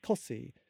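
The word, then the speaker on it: Costessey: So there's a place called Costacy, which is how you spell it, but you pronounce it Cossy.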